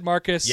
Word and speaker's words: Marquez: Marquise Buford. – When we called him that one day, he said Marcus. – Yeah. – But it's spelled Marquez. – Buford. – Marcus Buford. That is correct.